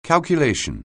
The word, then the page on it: calculation: anglais